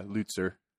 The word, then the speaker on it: Lutzer: And that quote, man, Erwin Lutzer, did I say that right? – Yeah, Lutzer.